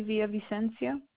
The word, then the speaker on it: Villavicencio: Leslie Villavicencio.